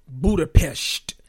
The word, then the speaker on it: Budapest: Now on to Budapest. Apparently that's the correct pronunciation.